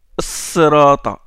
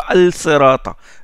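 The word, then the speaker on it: Sirata (الصراط): Sirata, on ne dit pas Al Sirata. Le Lam entre dans le Sad.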